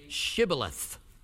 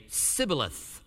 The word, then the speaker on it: Shibboleth: And they would say to him, then say Shibboleth. And they would say, Sibboleth, for he could not pronounce it right.